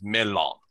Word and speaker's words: mellon: It is indeed spelled melon, M-E-L-L-O-N. but in Sindarin, which is the name of the language that Tolkien comes up with, it is pronounced melon.